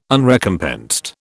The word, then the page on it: Unrecompensed: British English